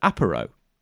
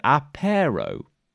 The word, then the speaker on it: Aparo: He says that I mentioned we were talking about Bax artist extraordinaire Jim Aparo, and Chris points out that we pronounce it in a very similar way to how we pronounce Amazo. – So correctly. – Amazo. Yeah. So, you know, we say it correctly, which is, Amazo, you know, and Aparo. And Chris, for some reason, seems to think it might be Amazo and Apero.